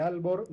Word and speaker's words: Aalborg: Aalborg, de Karup y de Skrydstrup.